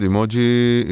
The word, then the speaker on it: emoji: Avoid saying emoji with a long e, שזה כמו email. Because the word comes from Japanese, eh means picture, and moji means character. כלומר, eh זה תמונה, ומוג'י זה דמות. The initial E is short vowel sound.